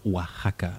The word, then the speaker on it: Oaxaca: Ah, par contre, on dit effectivement Oaxaca avec un X.